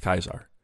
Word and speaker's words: Caesar: Tsar comes from Khazar, which comes from Kaiser, which comes from Caesar, which was originally pronounced Kaiser.